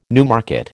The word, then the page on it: Newmarket: American English